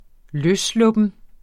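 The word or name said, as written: LØSSLUPPET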